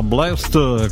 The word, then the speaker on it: BLXST: Si scrive BLXST, ma si pronuncia Blessed.